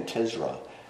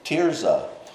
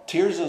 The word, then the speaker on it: Tirzah: In every talk I've given on Song of Solomon, I keep saying Tizra. Tirzah. Tirzah is one of the daughters of Zelophehad.